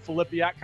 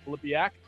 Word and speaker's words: Filipiak: So it is Filipiak, kind of like Philippians, but Filipiak.